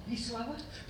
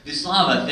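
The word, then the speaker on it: Wisława: I'm going to pronounce her first name wrong, help. – Vyslava? – Vyslava, thank you, Szymborska.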